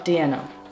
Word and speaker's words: Diena: Diena.